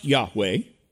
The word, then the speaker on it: Yahweh: Yahweh, Y-H-W-H. In Hebrew usage, it's pronounced Yahweh.